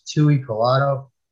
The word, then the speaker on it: Tuipulotu: I think it was Tuipulotu.